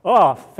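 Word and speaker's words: OAuth: Off, thank you. You pronounce it so much better than me.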